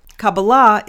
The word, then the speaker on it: Kabbalah: In Kabbalah, it's taught, Kabbalah is the correct Hebrew pronunciation.